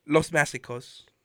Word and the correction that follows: Los Macizos: It's Macizos. – Los Macizos.